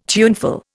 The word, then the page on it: tuneful: Indian